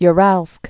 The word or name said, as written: Uralsk